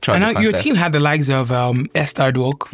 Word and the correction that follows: Odokwo: No, it's not Edwok. It's Odokwo.